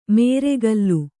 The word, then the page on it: ಮೇರೆಗಲ್ಲು: nēregallu